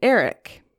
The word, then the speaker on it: Erech: Among these were Umbar, Amak, and Eric. Remember Eric? it's spelled E R E C H, but I just think of Eric like Eric Forman from that 70s show.